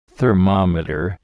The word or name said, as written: thermometer